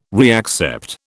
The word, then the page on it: reaccept: British English